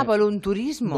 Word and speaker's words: volunturismo: Pues ah, volunturismo. – Volunturismo. – No voluntarismo, volunturismo.